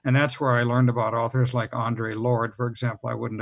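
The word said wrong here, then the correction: Audre: Um, Audrey.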